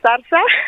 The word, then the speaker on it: Zarza: Vamos a arrancar por mi apellido, Zarza. – Ah, Salazar, dije.